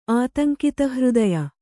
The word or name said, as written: ಆತಂಕಿತಹೃದಯ